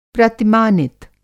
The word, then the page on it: प्रतिमानित: Hindi